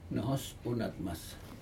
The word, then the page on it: nohos unatmas: Aneityum